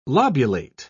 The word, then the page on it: lobulate: British English